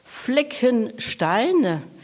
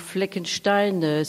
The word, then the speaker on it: Fleckenstein: Fleckenstein, Donc Fleckenstein,